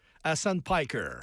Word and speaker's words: Hassan Piker: C'est un extrémiste de gauche qui s'appelle, je le prononce-tu bien, Hassan Piker? – Hassan Piker.